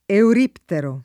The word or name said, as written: euriptero